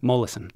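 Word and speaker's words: Molaison: Mollison.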